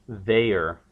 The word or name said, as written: they're